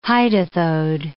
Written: hydathode

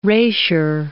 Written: rasure

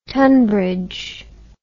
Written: Tonbridge

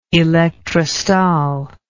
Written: elektrostal

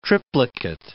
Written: triplicate

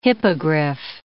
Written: hippogriff

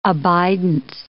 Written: abidance

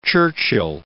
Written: Churchill

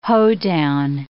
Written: hoedown

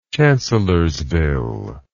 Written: chancellorsville

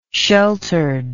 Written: sheltered

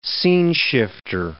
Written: sceneshifter

